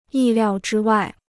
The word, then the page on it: 意料之外: yì liào zhī wài